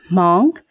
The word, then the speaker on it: Hmong: Hmong Americans. Mm-hmm. I'm not saying that because you said it with less H. – Yes, less H. Yep. It's the H is somewhat silent. It's Hmong. So, Hmong. Okay. And that's H-M-O-N-G.